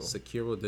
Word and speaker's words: Sekiro: Sekiro, is that how you pronounce it? – Sekiro, the new IP from Software.